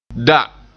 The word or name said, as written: da